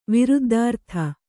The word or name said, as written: ವಿರುದ್ದಾರ್ಥ